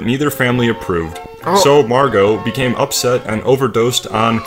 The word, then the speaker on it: Margot: I don't think he pronounced the T as Margot. – Margot.